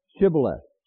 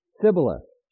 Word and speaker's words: Shibboleth: If he said nay, they said to him, say now, Shibboleth. And he said, Sibboleth, for he could not frame to pronounce it right.